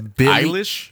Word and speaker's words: Billie Eilish: And here's a new one by Billie Eilish. Is that how you say it? – I guess. Yeah. – I think it's Billie Eilish. – Yeah, Billie Eilish.